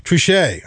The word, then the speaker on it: Trichet: Trichet, I guess that's how you pronounce him. He's from France. So Trichet, it's spelled T-R-I-C-H-E-T. So I guess that's Trichet.